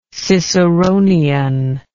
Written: ciceronically

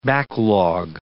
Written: backlog

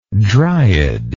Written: dryadic

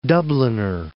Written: Dubliner

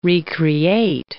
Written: re-create

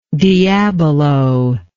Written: diabolo

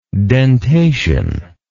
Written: dentation

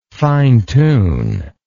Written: fine tune

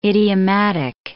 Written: idiomatic

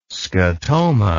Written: scatoma

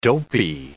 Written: dopy

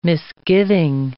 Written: misgiving

